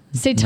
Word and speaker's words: seitan: So she's taught me so much with how she's done like dishes with, I think, seitan. Is that how you pronounce it? Oh, that's it's like a... – Ton? – Seitan. No. Seitan.